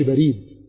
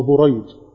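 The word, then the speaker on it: بُرَيد: شكل بقى بريد عشان ما تتنطقش بريد، عشان ما تتنطقش بريد، هو بريد. بريد، نعم، لا هو بريد، بريد، بريد ابن أبي مريم، بريد ابن أبي مريم.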